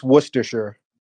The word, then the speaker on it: Worcestershire: It's Worcestershire.